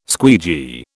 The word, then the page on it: squeegee: British English